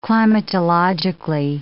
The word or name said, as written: climatologically